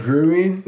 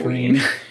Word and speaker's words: Gruene: Is that Greeween? – Greeween. – It's just pronounced green. – But that's how it's spelled? – Yeah, like G-R-U-E-N-E.